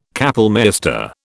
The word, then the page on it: kapellmeister: British English